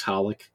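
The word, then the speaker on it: xxxHOLiC: Blood Sea, the second season of Genshiken, Haringu, however the heck you're supposed to pronounce XXX Holic. – Yeah. – I never remember. – It's just Holic.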